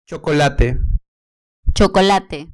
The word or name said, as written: chocolate